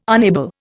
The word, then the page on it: unable: Indian